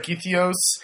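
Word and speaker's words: Gytheio: So, in this little fishing village of Githios? – Yes. – Did I say that right? Uh-huh. Or Githio? – Either way, I think.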